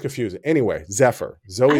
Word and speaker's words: Zephyr: Zephyr. I don't know why I want to say Zafar or Safari or whatever. It's literally spelled phonetically.